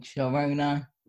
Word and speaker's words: Sharona: I had this other persona who I called Sharona. Rhymes with Corona.